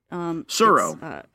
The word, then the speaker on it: Tsuro: It's like T-S-U-R-O. – Suro. – Okay. Suro.